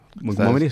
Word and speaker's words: Montgomery: ¿Montgomery se pronuncia? – Montgomery.